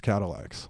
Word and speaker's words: Cadillacs: I mean, the first album and the first hit song is called Guitars Cadillacs. – Yeah. – Emphasis on the lax.